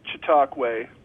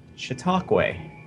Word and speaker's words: Chautauqua: Yes, it's pronounced Chautauqua. – Chautauqua. – You were close to us. – Having lived up in Watertown for a short period of time, that's what I heard. And looking at the word, it didn't seem to match, but that's how they say it.